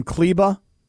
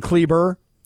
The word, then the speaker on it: Kleber: Are you calling him Kleba? – It's Kleba. That's how you say his name. – And I'm saying Kleber? – Yes.